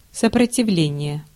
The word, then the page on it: stand: /ˈstænd/; American English; [ˈstænd]